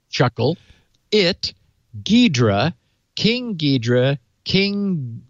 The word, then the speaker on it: Ghidra: It is pronounced Ghidra. – Yeah, that's what I thought. The H is silent. – Yes, Ghidra.